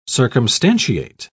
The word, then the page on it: circumstantiate: British English